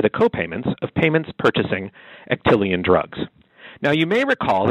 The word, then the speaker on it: Actelion: So on Thursday, the Justice Department announced that Actelion, and I wouldn't have known how to pronounce this without help from Mary Beth, but it's Actelion, like Actelion Pharmaceuticals,